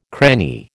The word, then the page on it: cranny: American English